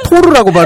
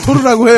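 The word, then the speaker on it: Thor: 도라고요? 소르요? – 또르 아닌가요? 또르? 왜? 또르 아닌가요? 또르? 이걸 토르라고 발음해요? 껄림내는 그렇게 영화하시나요? – 네, 우리 토르라고 해요.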